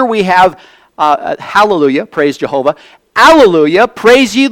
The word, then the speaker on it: alleluia: This is a Greek word, and when we say alleluia, we're actually pronouncing the Greek word. It's transliterated, not translated. Transliterated. So we're actually pronouncing the very Greek word that is in the text of this scripture.